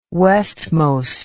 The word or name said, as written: westmost